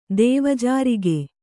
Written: ದೇವಜಾರಿಗೆ

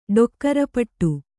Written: ಡೊಕ್ಕರಪಟ್ಟು

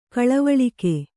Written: ಕೊಳವಾಳಿಕೆ

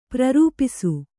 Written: ಪ್ರೇರೇಪಿಸು

